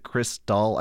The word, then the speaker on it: Xtal: it's spelled like X-T-A-L, but you're supposed to pronounce it Crystal, I found out, like, oh, God.